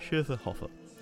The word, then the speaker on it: Schöfferhofer: Let me get the pronunciation right. Schöfferhofer. There's a little umlaut on the first O.